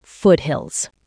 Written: foothills

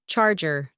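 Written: charger

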